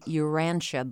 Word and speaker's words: Urantia: They were also avid followers of the Urantia book. Am I saying that right? – I think so. I'm pretty sure that's how you say it. I haven't heard it out loud that much myself. – U-R-A-N-T-I-A.